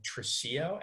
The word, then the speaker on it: tresillo: It's spelled tresillo, so it's a Spanish word. It's called tresillo, and this is just a rhythm pattern that is super common in popular music and then also in modern musical theater.